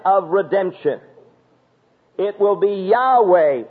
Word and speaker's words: Yahweh: should have made the pronunciation of this name for God not Jehovah, but literally Yahweh.